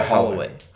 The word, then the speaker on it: Holloway: Yeah, Holloway.